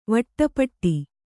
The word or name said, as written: ವಟ್ಟಪಟ್ಟಿ